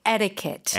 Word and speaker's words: etiquette: So, of course, it's American English, so we would say etiquette. – Etiquette. – Flap that T.